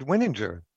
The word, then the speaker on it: Wininger: I pronounce it Winninger.